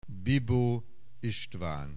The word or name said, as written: Bibo Istvan